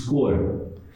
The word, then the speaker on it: cor: Picking colors, the word color in Portuguese is cor, and it rhymes with the word door.